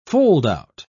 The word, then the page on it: foldout: British English